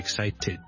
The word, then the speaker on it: excited: 我感到非常之興奮，I'm really excited。最尾要 ted 之後翻出嚟咧，我先至一個完整嘅發音嚟嘅。